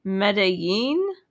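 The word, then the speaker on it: Medellín: not sure how this is pronounced. I looked it up. It's in Colombia. It's apparently in this video on YouTube, it's pronounced Medellin, where she survived by pickpocketing and selling her body.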